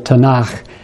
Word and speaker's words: Tanakh: The K, pronounced as a guttural, ch, at the end of Tanakh,